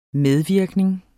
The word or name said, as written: medvirkning